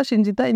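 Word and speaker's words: shingitai: Le fameux Shinjitai, quelque chose comme ça, mais je sais peut-être pas le prononcer. – Ouais, c'est pas exactement ça, Chinjitai, mais.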